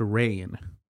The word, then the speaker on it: Rian: It's spelled R-I-A-N. It's pronounced Rian. I've also heard it pronounced Rain.